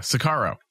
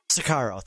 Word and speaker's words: Sicario: I can never pronounce it. Sirico? – Oh, yeah. Sicario. – Sicario. Thank you.